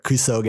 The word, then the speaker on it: kusoge: What are the Goners' favorite kusogi and why? – That's not a good pronunciation. – Give me that way. – What is the Goners' favorite kusoge game? – That sounds very similar to what I said.